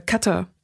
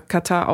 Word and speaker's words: Katar: Jetzt verstehe ich auch, warum alle das so leicht überbetont aussprechen, um es eben zu differenzieren von dem, von dem Katar, von der Entzündung, von, der, von dem Krankheitszustand. Dass man sagt, das Land ist ja kein Krankheitszustand.